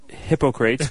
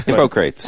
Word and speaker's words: Hippocrates: I think it's pronounced... Hippocrates. – Hippocrates, yes. – I believe it is Hippocrates.